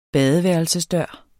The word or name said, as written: badeværelsesdør